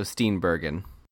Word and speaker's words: Steenburgen: They say a hard G, so Steenburgen.